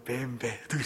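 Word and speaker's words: BMW: 그래서 이제 BMW가 원래 뱀베 이렇게 발음을 하는데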